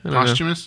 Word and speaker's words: posthumous: I don't think I said that right. Did I? – Posthumous. – Posthumous. Thank you.